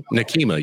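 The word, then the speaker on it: Nekima: How does she pronounce her name? I know it's hyphenated. In Minnesota. Levy Armstrong. – Oh, Nakima. Yeah, Nakima Levy Armstrong.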